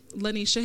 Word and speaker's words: Lanisha: So on Facebook, it's just Lanisha Harrell.